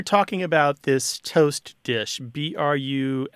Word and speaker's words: bruschetta: We always pronounced bruschetta with a K. And I've been, you know, everywhere it seems people pronounce it bruschetta with like a CH kind of sound.